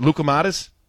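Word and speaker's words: loukoumades: And I always mispronounce it, but is it Lucomatis? – Yeah.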